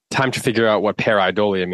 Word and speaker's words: pareidolia: The word for this week is pareidolia.